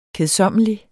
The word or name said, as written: kedsommelig